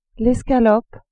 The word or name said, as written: escalope